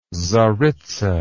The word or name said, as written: czaritza